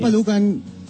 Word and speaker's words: sinampalukan: Did I say it right? – It's not sinigang. – Sinampalukan manok, diba? – Why? What's wrong with making sinigang with chicken? – No, it's called sinampalukan.